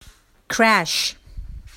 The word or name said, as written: crash